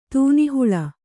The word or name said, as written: ತೆನೆಹುಲ್ಲು